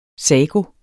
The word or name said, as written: sago